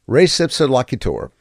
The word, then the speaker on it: res ipsa loquitur: I do know that you have to do the U's with a long U sound, but beyond that, I'm going to try this first one. Res ipsa loquitur.